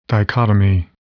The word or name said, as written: dichotomy